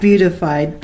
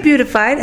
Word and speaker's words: beatified: She was beatified. – I didn't say beautified, I said beatified. Beatified. – Okay, beatified. – Not beat it up, but beatified.